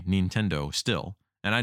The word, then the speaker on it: Nintendo: They always say Nintendo, which is the right way to say it in Japanese.